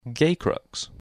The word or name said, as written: Gacrux